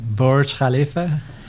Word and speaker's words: Burj Khalifa: Some of the other notable projects has been involved with was the famous Lakhta Towers in Russia and the, how do I pronounce that? – Burj Khalifa.